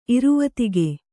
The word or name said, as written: ಇರುವತಿಗೆ